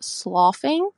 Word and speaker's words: sloughing: I'm a registered nurse and it's actually pronounced sloughing.